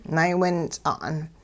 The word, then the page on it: naywints'a'n: hupa